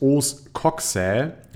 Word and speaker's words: Os coxae: Das Hüftbein heißt Os coxae, also C-O-X-A-E. Also A-E spricht man eigentlich immer Ä in Latein, zumindest ist das, was ich mir, äh, einer der wenigen Dinge, die ich mir aus dem Lateinunterricht gemerkt habe.